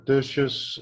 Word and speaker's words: Tertius: Yes, it's Tertius, and my surname is Fourie, yes.